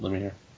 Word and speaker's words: Lemire: Some good Jeff Lemire, is that how you say it? Lemire? – Yeah.